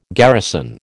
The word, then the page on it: garrison: American English